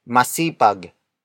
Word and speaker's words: masipag: The next word is masipag. Masipag. Masipag. Masipag is spelled as M-A-S-I-P-A-G.